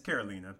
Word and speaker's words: Carolina: Now, once again, here's another example of why these type of siblings didn't make our top five, because although Carolina has, or is it Carolina or Carolina? – It's Carolina, but that's okay. – It's Carolina.